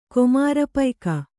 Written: ಕೊಮಾರಪೈಕ

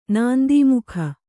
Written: ನಂದೀಮುಖಿ

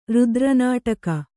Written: ರುದ್ರನಾಟಕ

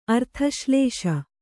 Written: ಅರ್ಥಶ್ಲೇಷ

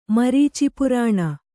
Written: ಮರೀಚಿಪುರಾಣ

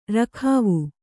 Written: ರಖಾವು

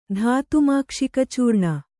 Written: ಧಾತುಮಾಕ್ಷಿಕಚೂರ್ಣ

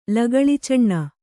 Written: ಲಗಳಿಚಣ್ಣ

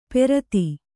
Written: ಪೆರತಿ

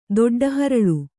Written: ದೊಡ್ಡಹರಳು

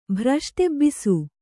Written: ಭ್ರಷ್ಟೆಬ್ಬಿಸು

